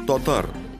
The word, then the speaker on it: татар: Например, «а» в начале слова произносится со свернутыми в трубочку губами. Получается «о». Что-то среднее между «а» и «о». Получается «татар». Что интересно, во втором и последующих слогах этот эффект пропадает.